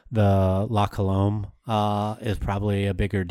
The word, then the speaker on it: La Colombe: La Colombe. Say that right? – Yeah. – Colombe. Awesome. Cool. La Colombe.